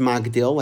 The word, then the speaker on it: Magdil: Now it's written Magdil, and in Tehillim it's actually pronounced Magdil, like we say during the week. But here the Kri is actually Migdol, like we say on Shabbos.